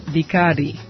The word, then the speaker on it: Bicardi: And this is called Captain Bicardi. It's not spelled with an A, but like the drink. It's B-I-C-A-R-D-I. Captain Bicardi.